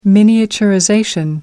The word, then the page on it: miniaturization: American English